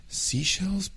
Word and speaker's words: Seychelles: O sea, Seychelles, bueno, debería ser como conchas del mar, pero se escribe diferente. Pero yo creo que así lo pronunciamos en inglés, ¿sí? Seychelles.